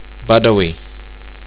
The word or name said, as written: budawi